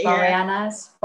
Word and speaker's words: Boreanaz: I probably can't pronounce his name right, but is it David Boreanaz? Boreanaz? Boreanaz or something, isn't it? Boreanaz.